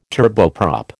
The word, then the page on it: turboprop: American English